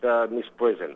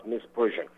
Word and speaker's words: misprision: Mike Rivera calls it misprision. Actually, it's pronounced misprision.